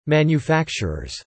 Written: manufacturers

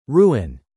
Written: Ruin

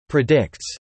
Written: predicts

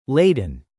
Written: laden

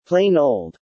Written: plain old

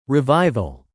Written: revival

